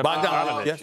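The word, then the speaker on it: Bogdanovic: Yeah, Bogdanovich.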